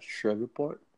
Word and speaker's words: Shreveport: Shreveport. All right, Shreveport, Louisiana.